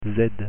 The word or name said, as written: z